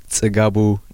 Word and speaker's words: Tsgabu: Tsgabu Gebremariam Grmay.